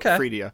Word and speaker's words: Freedia: F-R-E-E-D-I-A.